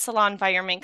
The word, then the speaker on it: Salonvironment: So I'm going to let Hannah speak for herself, and we can jump into the interview with Hannah from Salon Environment. For my listeners that don't know, and you know, I keep pronouncing your business incorrectly. I keep saying Salon environment, but it's salon environment, right? – Yes.